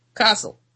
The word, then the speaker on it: Kassel: The alliance with the physics of the atmosphere was unreliable, but it became an unscheduled addition to the agenda in Kassel. I've learned that that's pronounced Kassel in October 1943. It's a town spelled K-A-S-S-E-L.